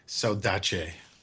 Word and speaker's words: saudade: Oh, is that... is that a different way of pronouncing a word? Saudade? No. Oh. – Oh, that's actually how you pronounce that word, actually. – It's not actually. So. – In Portuguese? – Yes.